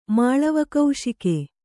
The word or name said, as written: ಮಾಳವಕೌಶಿಕೆ